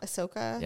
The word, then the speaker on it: Ahsoka: Ahsoka? – Yep.